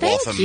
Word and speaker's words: Waltham: Thank Waltham, Massachusetts. – Thank you for pronouncing it Waltham.